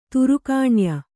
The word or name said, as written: ತ್ರೈಗುಣ್ಯ